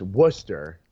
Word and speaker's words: Worcester: Probably three or four, because it's Worcester, but you hear a lot of Worcester.